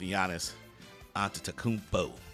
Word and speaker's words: Antetokounmpo: Again, I don't know who they polled here, but I can get with Giannis Antetokounmpo.